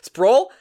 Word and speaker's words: Sproul: Because you're calling up Ryan Sprawl, who I definitely mispronounced his name just right there. – I'm pretty sure it's just Sprawl. Like I think this was an this is an easy one. It's pronounce that as, it's written. as written reading is hard.